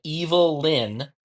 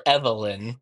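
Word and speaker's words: Evil-Lyn: it all started when Evil Lyn, or Evelyn, but it's spelled Evil hyphen Lyn.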